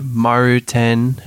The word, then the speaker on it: Maruten: I go by Maruten. It's like the Japanese version of my name, but I spell it with a 7.